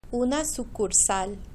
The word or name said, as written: una sucursal